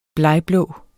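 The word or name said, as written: blegblå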